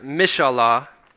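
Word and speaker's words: mishallah (מִשְׁאָלָה): The term translated desires, pronounced mishallah, refers to petitions or requests.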